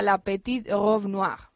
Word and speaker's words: La Petite Robe Noire: También en la Maison Guerlain hay un despliegue en torno, a ver si lo digo bien porque está en francés, en torno sí. A la Petite Robe Noire. ¿No es así? – Fantástico.